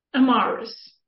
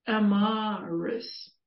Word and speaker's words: Emirus: Emirus, is that how, the correct pronunciation? – Emirus. – Oh, it's almost like an A then rather than an I. – Well, it's both. Emirus. Emirus.